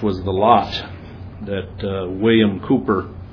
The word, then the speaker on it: Cowper: The name looks like it should be pronounced Cowper, but it's Cooper.